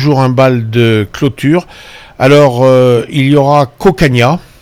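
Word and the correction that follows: Cocanha: On prononce pas le A. Hein. – Voilà, c'est ça. C'est Cocagne.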